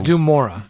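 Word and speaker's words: Dun Morogh: I don't know if the outside is Dunmoreau or it's or it's Dumora. It Dumora. – Is that really how you say it? – Yes, that's it, is Dumora, and that's where he ended up in.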